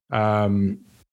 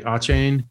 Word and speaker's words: Achane: Achane, is that how you say it, right? Achane? – I think so. Yes.